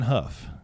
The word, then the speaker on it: Houff: Quinn Huff, and it's spelled Houff.